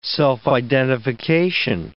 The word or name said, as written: self-identification